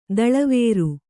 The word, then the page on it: ದಳವೇರು: daḷavēru